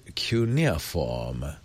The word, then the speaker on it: cuneiform: All right, now somebody's passing along the Cambridge Dictionary pronunciation of the word cuneiform. How to pronounce cuneiform in British English? – Cuneiform. – He is saying. – There you go. – There it is. – All right. – Yep. Cuneiform.